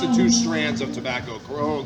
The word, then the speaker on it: Corojo: But how do you pronounce the Corojo? – Corojo. – Corojo.